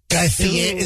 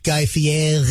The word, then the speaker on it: Guy Fieri: You got to go Guy Fieri. There's some kind of twisted contortion you got to do with the middle of his last name.